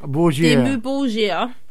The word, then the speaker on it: Dimmu Borgir: Dimmu Borgir.